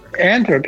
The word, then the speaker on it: Antwerp: I want to come and visit Antwerp. Am I saying that right? – Antwerp. Yeah.